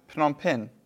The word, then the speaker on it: Phnom Penh: In English, it looks very weird to pronounce because it's Phnom. So, like, if you haven't heard it, you're going to have a tough time, you know, pronouncing it. – How do you pronounce it? Phnom Penh. – Phnom Penh.